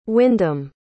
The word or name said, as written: wyndham